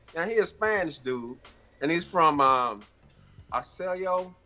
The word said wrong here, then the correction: Osceola: County, Florida. Did I say that right? Osceola, Osceola.